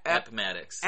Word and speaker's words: Appomattox: Appomattox.